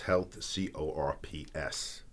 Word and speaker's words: Health Corps: That's Health, C-O-R-P-S, pronounced Health Corps, which is a nonprofit organization designed to educate the student body on nutrition, exercise, chiropractic, and more importantly, mental resilience.